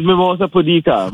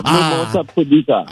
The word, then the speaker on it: Mimosa pudica: It says Mimosa pudica. Mimosa pudica.